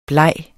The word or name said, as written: bleg